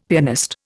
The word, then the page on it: pianist: Indian